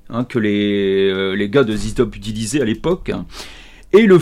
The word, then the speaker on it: ZZ Top: Parce qu'il faut le prononcer Zizi Top.